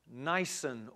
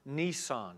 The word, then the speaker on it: Nisan: an Aramaic word called Nisan or Nisan. It's pronounced two different ways.